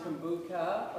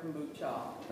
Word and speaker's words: kombucha: This is, what do you call it, kabunka? Kabunki? – It's pronounced kombucha or kombucha.